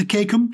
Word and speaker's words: cecum: I mean, the colon itself, obviously, is the largest part of the large intestine, extending from the, is it the cecum to the rectum? Is that how you pronounce it? – Yes.